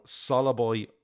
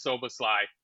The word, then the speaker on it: Szoboszlai: But it's actually Szoboszlai, is it? – So this is the Google that I saw. It says it's three syllables. First syllable is S-O-W, second is B-O-W, and the last one is S-L-A-I. And that's not a very great translation, because S-O-W and B-O-W are homonyms.